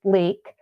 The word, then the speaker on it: Leeke: Well, I have a YouTube channel under my name, Lori Tilden Leek. – Leek is spelled L-E-E-K-E, everybody.